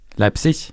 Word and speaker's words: Leipzig: Vil du si at Leipzig var en riktig uttalelse? Eller Leipzig? Leipzig.